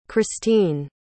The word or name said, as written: christine